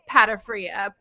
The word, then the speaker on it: Pata Fria: So I say it Pata Fria, okay, to make it easy for people. It's, of course, Pata Fria, but I'm not going to put all that.